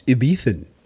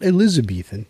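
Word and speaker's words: Ibizan: No, it's Ibethan, not Elizabethan, just Ibethan. – Please work with me on this pronunciation. I'll yeah, like that. Yeah. yeah, you can you can do that. It's more correctly Ibethan.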